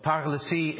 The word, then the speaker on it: paralysie: And of course, those English couldn't pronounce that, so we just said palsy. But paralysie is paralysis. It's just their French word for paralysis. So that's what palsy is. It's our pathetic attempt at pronouncing a French word.